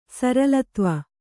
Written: ಸರಲತ್ವ

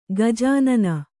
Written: ಗಜಾನನ

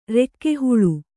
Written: ರೆಕ್ಕೆಹುಳು

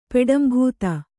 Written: ಪೆಡಂಭೂತ

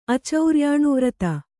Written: ಅಚೌರ್ಯಾಣುವ್ರತ

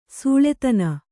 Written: ಸುಲ್ತಾನ್

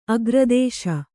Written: ಅಗ್ರದೇಶ

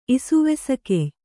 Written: ಇಸುವೆಸಗೆಯ್

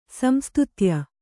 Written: ಸಂಸ್ತುತ್ಯ

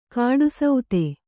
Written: ಕೂಟಸ್ಥ

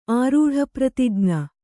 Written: ಆರೂಢಪ್ರತಿಜ್ಞ

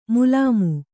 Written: ಮೇಲ್ಮೈ

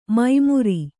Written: ಮೈಮುರಿ

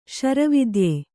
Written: ಶರವಿದ್ಯೆ

